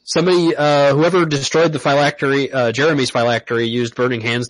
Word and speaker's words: phylactery: Says here, phylactery. I said that right. Right? That's yep.